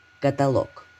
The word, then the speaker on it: каталог: Каталог. Здесь, как и в предыдущем случае, есть единственно верный вариант произношения. Правильное место ударения в слове на звук «о» при любых значениях слова. Исключений нет – Фраза для запоминания.